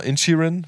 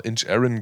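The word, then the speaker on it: Inisherin: Ich dachte, es heißt immer Inchirin, weil das viele Rezensenten so ausgesprochen haben, aber in der deutschen Synchro haben sie tatsächlich immer Inch Aaron gesprochen.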